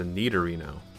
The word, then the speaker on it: Nidorino: Um, say the two Pokemon again because I think you may have mispronounced it. – Gengar and Nindorino. Nindorino? Nindorino. – Uh, false. It was a Nidorino.